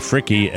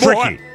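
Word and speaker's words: Fricke: Not as bad as when I pronounced Janie Fricky as Janie Frick. Like with the thing, it was the old English E. Jim Randall called up, boy, that's Fricky.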